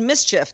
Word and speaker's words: MSCHF: This is a project from a company called Mischief, that's spelled M-S-C-H-F.